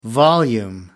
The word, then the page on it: volume: American English